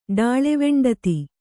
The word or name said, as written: ಡಾಳೆವೆಂಡತಿ